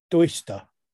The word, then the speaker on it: Duyster: Then there was a feature film called I think it's pronounced Duister. – Okay. – Spelt Duyster.